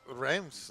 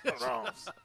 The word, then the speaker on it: Reims: And that always amused me because if you ever heard a Frenchman pronounce that name, how would you pronounce it? Reims. That's where all the champagne, that's the headquarters of champagne production in France. So it's Reims, no? – Well, you're closer than most people.